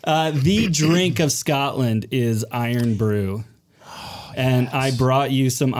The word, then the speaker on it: Irn-Bru: But this is your unicorn mug, but I get to drink the Iron Brew in the, it's Iron Brew, I-R-N-B-R-U. Iron Brew. – That's exactly how they say it, by Brood the way, when you order it.